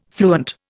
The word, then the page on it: fluent: Indian